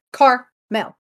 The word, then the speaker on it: Carmel: Carmel or caramel? – Well, there's no second A. It's just like Carmel.